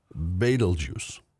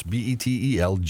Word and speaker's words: Betelgeuse: Now, I looked this up. There's many pronunciations. I'm going with Betelgeuse. – All right. – Okay, it's Betelgeuse.